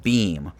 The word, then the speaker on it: Beme: is something called Beme, and it's spelled like if you if you put the words Beam be and me together, so be me. Um, but the the word is pronounced beam, um, and essentially it's this app.